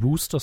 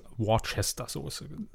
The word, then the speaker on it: Worcester: Das ist auch, so wird ja auch eigentlich die, die Worcester Sauce, wie man im Deutschen immer gern sagt, ausgesprochen, die Wooster Sauce. – Denk wie heißt es denn richtig? – Ja, Wooster. Wooster Sauce. Ja, ne? So Achso, und, und sie meinten im Deutschen wird es Worcester-Soße.